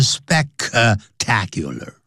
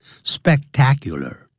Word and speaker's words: spectacular: 在这个夜总会的这些墙壁里头，就在夜总会里聚会的，干什么呢？To enjoy the spectacular shows and ambience，来享受这种壮观的 spectacular，因为有一个 s p e c，所以念的时候要停顿一下 spectacular。 Spectacular, 不要念成 spectacular, 啊 spectacular, 很壮观的这些表演 shows, 节目表演 and ambience, 就是氛围也就是气氛就等于 atmosphere.